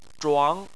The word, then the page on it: zhuang: 2